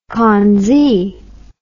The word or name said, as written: Kongzi